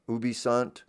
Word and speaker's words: ubi sunt: Ubu Sunt or something, isn't that how you say it? Ubi Sunt.